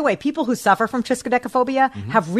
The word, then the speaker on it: triskaidekaphobia: I thought that was brisket phobia, but I think it was triskaidekaphobia.